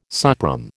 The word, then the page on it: suprême: American English